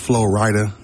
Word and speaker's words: Flo Rida: Flo Rida. Who is Flo Rida? Do you know who this is? – It's pronounced Flo Rida.